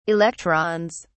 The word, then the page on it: electrons: British English